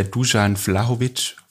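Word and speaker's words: Dušan Vlahović: Wenn die aber jemand macht, dann ist der Dusan Vlahovic vorne drin.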